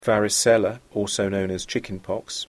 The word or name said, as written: varicella